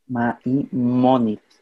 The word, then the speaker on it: Maimónides: Maimónides.